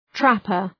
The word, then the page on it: trapper: ‘træpər